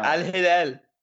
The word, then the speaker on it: Al Hilal: Sebastian Giovinco leaving for Al Hilal. Did I say that right? – Yeah, Al Hilal. Come on, you got to throw your accent in there.